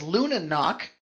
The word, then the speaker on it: Lunanoc: It is Luna Knock. Lunanoc. It is a portmanteau of Luna and Dobranok, which is Polish for good night, and it means good night.